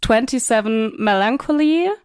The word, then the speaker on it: 27 Melancholy: Genau, Anfang Dezember ist ja deine EP, so, und jetzt musst du mir ein bisschen helfen, wie ist die korrekte Aussprache? Ist es 27 Melancholy? – Ja, genau.